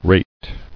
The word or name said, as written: Rated